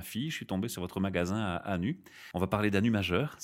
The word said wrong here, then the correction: Hann'ut: Oui, petite précision, ça se prononce Anut majeur.